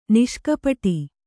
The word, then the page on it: ನಿಷ್ಕಪಟ: niṣkapaṭa